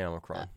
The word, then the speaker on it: Omicron: We're Omicron. How do you pronounce that? – I say Omicron.